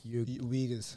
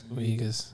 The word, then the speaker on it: Uyghurs: Uyghurs. – Uyghurs, yeah.